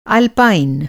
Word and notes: अल्पाइन: Hindi

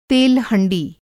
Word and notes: तेल हँड़ी: Hindi

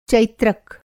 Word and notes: चैत्रक: Hindi

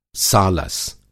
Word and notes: सालस: Hindi

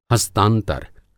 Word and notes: हस्तांतर: Hindi

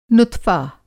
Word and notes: नुत्फ़ा: Hindi

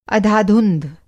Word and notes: अधाधुंध: Hindi

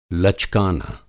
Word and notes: लचकाना: Hindi

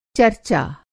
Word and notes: चरचा: Hindi

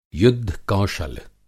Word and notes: युद्ध-कौशल: Hindi

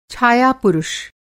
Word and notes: छायापुरुष: Hindi

